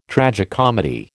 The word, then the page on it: tragicomedy: American English